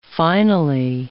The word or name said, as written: finally